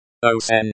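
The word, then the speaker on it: Osin (осень): Osin, with the stress on the first syllable.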